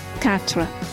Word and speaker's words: quatre: Quatre.